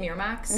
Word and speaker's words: Miramax: Is that how you say it? Miramax? Mm-hmm.